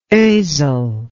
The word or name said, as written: Osel